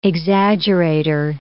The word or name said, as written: exaggerator